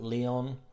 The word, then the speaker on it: Leon: If you know about Leon restaurants around the UK, L-E-O-N, Leon, I think some places around the world pronounce it Leon.